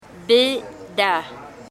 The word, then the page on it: Beda: Embera Chamí